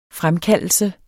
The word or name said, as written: fremkaldelse